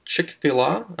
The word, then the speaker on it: Chick-fil-A: No, I believe Chick-fil-A is how we say it here in the United States, but I'm not for sure how they pronounce it in Canada. – I've already alienated most of my listeners. So Chick-fil-A, F-I-L-A,